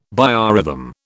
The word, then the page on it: biorhythm: British English